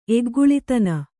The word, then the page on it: ಎಗ್ಗುಳಿತನ: Kannada